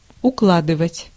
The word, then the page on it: pile: [ˈpɑɪəl]; /paɪl/; /ˈpɑɪl/